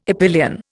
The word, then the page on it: epyllion: Indian